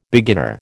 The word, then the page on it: beginner: American English